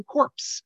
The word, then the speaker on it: Corps: You can go on YouTube and you can see Obama when he referred to Marine Corps. No one told him that the S in that is silent.